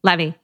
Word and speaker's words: Levey: Is that how you say it? Levy? Levy.